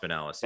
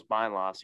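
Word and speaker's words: Binelas: Is it Benelis? Is that how it's pronounced? – Yeah, Alex Benelis. – Man, yeah, I thought it was Bynelos the entire time.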